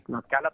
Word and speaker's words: Tlaxcala: Y estos días hay Tlaxcala o algo así, ¿verdad? Que lo retransmite Juan Toro. ¿Cómo se dice? – Tlaxcala también, sí.